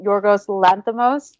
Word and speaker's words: Yorgos Lanthimos: And I would have loved to have seen, I'm sorry if I butcher his name, Yorgos Lanthimos. – Yes, that's how you director say it.